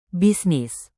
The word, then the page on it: bisnis: indonesian